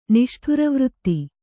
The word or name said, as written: ನಿಷ್ಠುರವೃತ್ತಿ